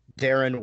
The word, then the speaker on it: Daeron: I am probably the best one to interact with me would be at Darren Wilson, D-A-E-R-O-N, Wilson the traditional way, but my parents took my name out of the Silmarillion by Tolkien.